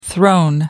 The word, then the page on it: throne: American English